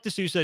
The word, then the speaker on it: De Sousa: It looks like De Sousa. It is actually pronounced De Sousa, though.